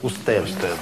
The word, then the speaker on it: usted: «Устед» пишется с большой буквы. Откуда она взялась? Это сокращенная форма словосочетания «ваша милость». «Vuestra merced». Но со временем... как все сократилось и упростилось, и стало произноситься «usted».